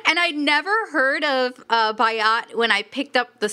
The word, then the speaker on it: Byatt: Biot. Is that how you pronounce her last name? I'm not sure. – It's actually Byatt. A.S. Byatt.